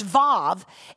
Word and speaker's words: Vav: In the very first place that you actually see this letter, which is pronounced Vav, is actually in Genesis chapter 1, verse 1.